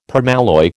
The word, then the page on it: permalloy: American English